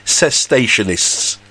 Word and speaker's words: cessationists: Sensationalists. No? It's not sensationalists, Sanji. It's cessationists. It's not sensationalists. It's cessationists. As it's spelt, is how it is.